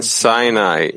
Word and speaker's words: Sinai: and Sinai means Sinah, hatred, for they are pronounced the same, meaning affliction entailed in every illness.